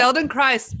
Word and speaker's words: Feldenkrais: And I guess, and I hope I'm saying this correctly, Feldenkrais? Feldenkrais? Yeah. Feldenkrais, Feldenkrais, Feldenkrais.